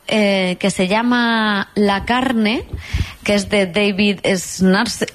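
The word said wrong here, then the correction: Szalay: Ahora pronunciaré bien su nombre. David Salai.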